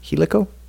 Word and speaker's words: Helico: Helico. – Yeah, Helico? Like helical, but Helico. – Oh, okay. – Like he let go of her.